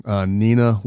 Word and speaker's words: Neenah: We have a team from Wisconsin, Neenah, Wisconsin.